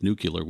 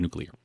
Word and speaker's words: nuclear: and he used nuclear, which seems to be a Texas thing, and it seems to have slipped back in. And quite frankly, I grew up with a father in the electric industry, and he always lectured me whenever somebody said nuclear, so I've always heard nuclear. – Nuclear, which is the mispronunciation, as you said, is actually a very common mispronunciation, and there's actually a linguistic reason for that that we won't go into here.